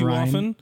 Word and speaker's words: Ryne: My name is Ryne Sandberg. After Ryne Sandberg, my name is spelled R-Y-N-E. I get called fucking Renee.